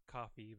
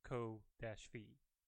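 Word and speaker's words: Ko-fi: I know it's supposed to be pronounced coffee, but to me it's co-fee.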